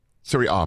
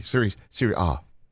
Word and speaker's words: Serie A: Three of them come from Serie A in Italy. Beautiful country of Italy. Serie A, pardon me. Serie A, my bad.